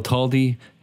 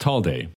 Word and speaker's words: Talde: This got me talking to Dale about his own last name, which, as I understand it, is typically pronounced Talde. But I noticed that he himself usually pronounced it Talde. – Talde is like Talde. – Yeah. – You know, you have to have, it comes from a Spanish name.